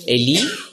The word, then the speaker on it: Eli: Wie spricht man das aus, korrekt? Eli, Eli, mein Gott.